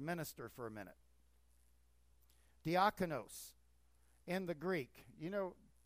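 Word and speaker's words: diakonos (διάκονος): Minister, diakonos in the Greek. That's how it's pronounced.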